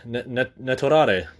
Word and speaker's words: Netorare: But then there's the dark side, like Naotare, Netorare. I said it correctly this time. Netorare is Netorare, NTR.